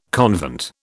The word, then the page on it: convent: British English